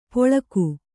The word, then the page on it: ಪೊಳಕು: poḷaku